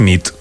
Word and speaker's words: nit: Y noche es nit. – Muy bien. Buena nit. – Buena nit, es verdad. – Buena nit.